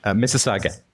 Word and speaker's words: Mississauga: Mississauga.